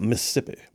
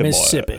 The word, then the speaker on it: Mississippi: Notice how I say that? Mississippi. I say Mississippi, Mississippi. – Boy. Yeah, you don't do every syllable. It's mm-mm. Mississippi.